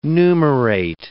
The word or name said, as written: numerate